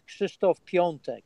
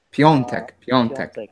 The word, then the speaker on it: Krzysztof Piątek: Can you give us the proper pronunciation? – Yeah, he's a tough one, although not as tough as Wojciech Szczęsny, you know, that you have at Juve. But his name is Krzysztof Piątek. – Piontek, Piontek.